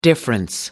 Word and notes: difference: American English

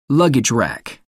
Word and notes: luggage rack: American English

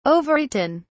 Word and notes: overeaten: British English

